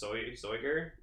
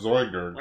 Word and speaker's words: Zoiger: Zoigerd. – Oh, it's Zoiger? – Why not Zoigerd? – Well, I thought it was Zoiger, like Voyager. – I think so. – I think it's pronounced Zoiger.